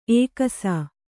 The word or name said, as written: ಎಕ್ಕಿಸು